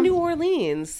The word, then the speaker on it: New Orleans: Just say New Orleans. That's fine. – You can't say New Orleans.